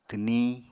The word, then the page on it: twniñ: apatani